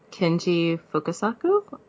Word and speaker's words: Kenji Fukasaku: One of them is Battle Royale from Kenji Fukasaku, if I pronounced his name right.